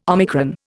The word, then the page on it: omicron: Indian